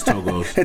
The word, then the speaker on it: Togo's: And is that how you say it? Is it Togo's? Whenever I see it, I thought it was Togo's. – It's Togo's.